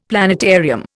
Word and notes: planetarium: Indian

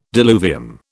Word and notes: diluvium: British English

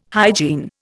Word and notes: hygiene: Indian

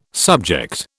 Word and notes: subject: British English

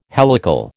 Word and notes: helical: American English

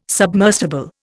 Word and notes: submersible: Indian

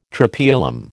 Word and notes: Tropaeolum: American English

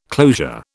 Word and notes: closure: British English

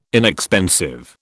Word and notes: inexpensive: British English

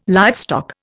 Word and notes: livestock: Indian

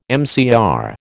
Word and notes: MCR: American English

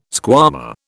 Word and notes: squama: British English

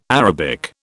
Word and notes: Arabic: British English

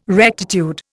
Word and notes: rectitude: Indian

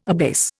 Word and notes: abase: Indian

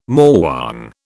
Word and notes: morwong: British English

